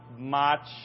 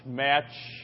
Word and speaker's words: Mach: You know, praying for the the Bob Mach Mach, Match, family or however they pronounce that, M-A-C-H.